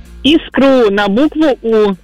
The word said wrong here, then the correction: искру: Эх, а сначала-то искру сказали. С пятерочкой по русскому языку. Давайте разбираться. Согласно литературной норме русского языка, ударение в данном слове всегда падает только на первый слог. Поэтому правильно говорить искру.